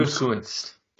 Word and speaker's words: Gutekunst: Gutekunst.